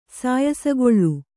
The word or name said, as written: ಸಾಯಸಗೊಳ್